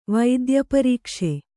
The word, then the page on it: ವೈದ್ಯಪರೀಕ್ಷೆ: vaidya parīkṣe